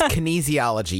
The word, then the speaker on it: kinesiology: Okay, it's kinesiology. You were right. I was mispronouncing it. This is a terrible start, it's kinesiology.